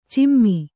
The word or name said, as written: ಚಿಮ್ಮಿ